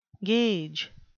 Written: gage

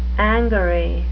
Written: angary

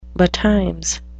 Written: betimes

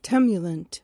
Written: temulent

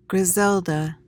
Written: griselda

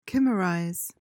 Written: chimerize

